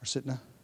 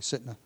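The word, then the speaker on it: Sitnah: Sitka translates as hatred. Or Sitna? I pronounced it incorrectly. Sitna translates as hatred.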